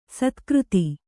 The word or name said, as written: ಸತ್ಕೀರ್ತಿ